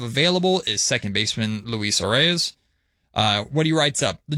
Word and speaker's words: Arraez: Arraez appropriately rhymes with prize, and he's just that for contending teams that recognize bat-to-ball skills go a long way in the postseason.